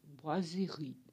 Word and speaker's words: boiserie: Boiserie é uma palavra de origem francesa, a pronúncia é boiserie faz biquinho.